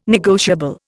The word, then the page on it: negotiable: Indian